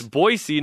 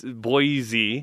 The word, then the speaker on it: Boise: Every time someone says it wrong, I have to hear there's no Z in Boise.